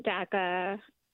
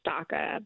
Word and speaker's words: DACA: Well, I was on DACA. I know people be trying to say it's DACA, but we pronounce it DACA.